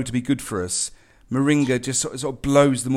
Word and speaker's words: moringa: Now, there's probably lots of people tuning in to the show that have no idea what moringa actually is. Am I even pronouncing that right? – You are. You're pronouncing that absolutely right.